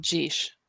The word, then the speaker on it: geis: I did found another source that said the Irish spelling is G-E-I-S, and that is spelled geesh. Or geesh. Geesh. It's spelled geesh. Geesh. It pronounced geesh. And it's spelled G-E-I-S. But G-E-A-S is a different dialect.